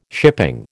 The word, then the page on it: shipping: American English